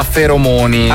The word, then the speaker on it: feromoni: C'era un altro ragazzo che ci ha mandato un messaggio prima di andare sul broncotest, che ci ha scritto che non si dice ferormoni, ma feromoni. – Ah. – In realtà ho fatto una ricerca allora è più corretto dire feromoni, ma si può dire anche ferormoni.